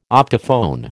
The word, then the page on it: optophone: American English